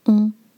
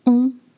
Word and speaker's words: on: You've noticed that the spelling is on, and you do not pronounce on. I know it's the same spelling, but it's a different language. So, you start with the sound O, O, and then try to lift the back of your tongue and make the air go through your nose.